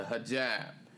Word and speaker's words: hijab: And now I want to go to this next clip, and this is going to be the hijab. That's how you pronounce it, the hijab.